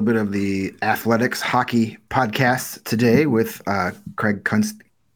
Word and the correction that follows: Custance: I can't say his name. – Custance. – Custance. It's, I get myself in trouble with that one. Like, there's, yeah. I always want a certain extra letter that's illegal.